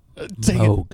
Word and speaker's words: Moog: Moog. – Moog bass. – Moog. Rhymes with Vogue. Vogue.